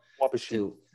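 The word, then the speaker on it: Wabasha: I can't pronounce the name of the town that you're in, so I'll leave it to you. – Wabashin. – There you go. You got it.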